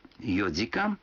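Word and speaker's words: йодзикан (四時間): Но будьте внимательны, некоторые цифры в этих случаях произносятся не так, как обычно. 4 часа – это йодзикан, 7 часов – ситидзикан, а 9 часов – кудзикан.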